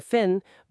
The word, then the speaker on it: fin: O exemplo foi, the fish has a broken fin, but the weather is fine. Percebe a diferença no som? Fin, fine.